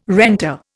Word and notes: renter: Indian

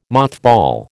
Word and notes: mothball: American English

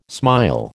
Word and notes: smile: American English

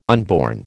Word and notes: unborn: American English

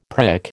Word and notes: prick: American English

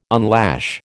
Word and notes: unlash: American English

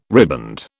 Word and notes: riband: British English

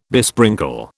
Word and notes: besprinkle: British English

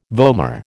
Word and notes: vomer: American English

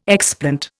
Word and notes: explant: Indian

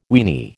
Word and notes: weeny: American English

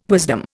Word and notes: wisdom: Indian

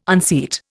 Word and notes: unseat: Indian

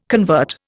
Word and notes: convert: Indian

